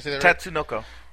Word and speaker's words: Tatsunoko: Tatsunoko. – Tatsunoko. – That's close enough.